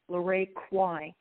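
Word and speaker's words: LaRae Quy: And my name is spelled L-A, capital R-A-E, and the last name is Q-U-Y, Lorraine Quay.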